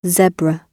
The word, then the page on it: zebra: British English